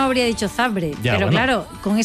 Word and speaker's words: Zabrze: ¿Por qué pronuncia Zabrze? – Porque es así.